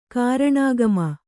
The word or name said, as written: ಕಾರಣಾಗಮ